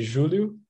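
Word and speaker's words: Júlio: I'm from Brazil, and there we say Julio, but I'm totally fine with Julio, so no problem.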